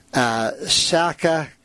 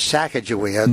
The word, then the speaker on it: Sacagawea: Now, in North Dakota, they pronounce it Sacagawea, like with a K. – With a K. – Yeah, but we know it as Sacagawea.